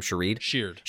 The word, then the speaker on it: Sheard: It makes so much sense to bring back Jabal, how do you say his last name? Sheard.